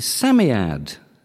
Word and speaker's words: Psammead: Nesbitt's The Story of the Amulet both have names that begin with the same silent letter. Which letter? – P. – Yes, as in Smith or Smith, and the Psammead.